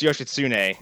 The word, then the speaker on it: Yoshitsune: Yoshimitsu, yes? No, that's the guy from Tekken. – Yoshitsune. – I think it's pronounced Yoshitsune. – Yeah, I think that's probably more accurate.